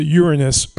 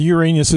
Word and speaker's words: Uranus: Or actually, as Carl Sagan says, you're supposed to pronounce it Uranus. But we're childish here, and Uranus is good.